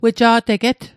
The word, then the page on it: weja'teget: Mi'gmaq